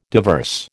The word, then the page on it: diverse: American English